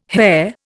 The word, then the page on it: hare: Indian